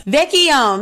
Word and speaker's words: Vecchione: Vecchione? – Close, yes. Vecchione, yes. – Vecchione, Ms. Natalie Vecchione.